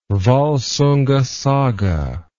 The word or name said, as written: volsunga saga